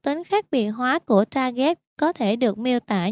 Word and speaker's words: Target: Những người yêu thích Target thường gọi Target theo cách phát âm tiếng Pháp là Target.